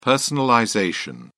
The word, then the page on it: personalization: British English